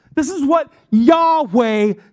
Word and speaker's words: Yahweh: Yahweh is how the Hebrews pronounce it.